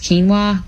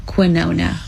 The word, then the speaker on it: quinoa: Meanwhile, this man pronounced quinoa quinona when he was ordering his food.